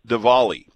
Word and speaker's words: Diwali: about today being Diwali. Brett McGarry, who is big on pronunciations, points out that the W is pronounced as a V, but I'm sure Diwali is fine too.